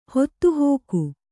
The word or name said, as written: ಹೊತ್ತುಹೋಗು